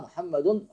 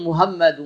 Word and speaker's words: محمد: محمد ومهومي، ما هوش اسم الرسول، مهومي محمد ما هوش اسم الرسول، لابد الحاء تنطق والميم مشددة، محمد قولش محمد، مش رسول، مش محمد، محمد ميم مشددة في اللغة